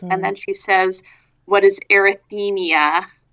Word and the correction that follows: arrhythmia: which unfortunately is a mispronunciation. There's no vowel between the TH and the M of arrhythmia.